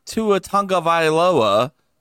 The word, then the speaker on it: Tua Tagovailoa: I decided to take a chance, a risk, they call it, and sit Tua Tagovailoa.